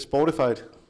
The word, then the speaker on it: Spotify: Hvad er det? Spotify? – Ja. Udtaler rigtigt? Det er fuldstændig udtalt rigtigt.